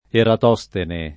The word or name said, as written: Eratostene